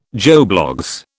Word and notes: Joe Bloggs: British English

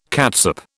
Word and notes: catsup: British English

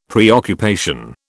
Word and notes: preoccupation: British English

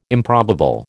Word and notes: improbable: American English

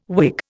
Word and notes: Whig: Indian

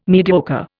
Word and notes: mediocre: Indian

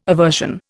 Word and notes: aversion: Indian

